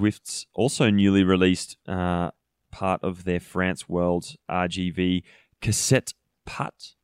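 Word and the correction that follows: Casse-Pattes: route. Probably butchered that name. – Yeah, the Caspat route.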